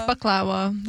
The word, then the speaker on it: baklava: You actually mispronounced baklava. – But I usually say baklava. – It's baklava. That's how you're supposed to say it.